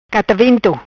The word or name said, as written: Catavento